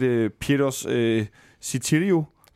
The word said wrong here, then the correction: Sotirio: Var det? – Øh, Sotirio. Sotirio. Sotirio. Ja.